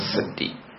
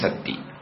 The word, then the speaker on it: sati: Mindfulness in Pali is called, in Burmese pronunciation, is called the Dhi. In Sri Lankan and Indian pronunciation, it's called Sati. In Thai translation, Thai pronunciation, it's called Sati. Okay, the same word.